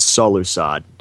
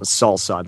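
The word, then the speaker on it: Solusod: So, yes, it's Solusad, not Salsad like I thought it was.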